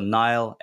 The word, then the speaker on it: Niall: So Niall, N-I-A-L-L. And I always say pronounced like the river.